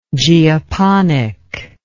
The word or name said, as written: geoponic